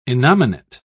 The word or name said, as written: innominate